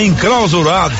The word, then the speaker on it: enclausurado: A gente fica tão encrausulado. Como é que fala encrausulado? – Encrausurado? – Não consegue, né? – Ô, seu Val, me deixa em paz, bicho. – Não consegue, né? – Consigo, senhor. É enclausurado.